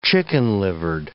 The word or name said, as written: chicken-livered